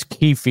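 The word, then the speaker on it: Keffe: That's Keefe to you. Keefe, like Keith. Oh, okay. Like, well, Keith.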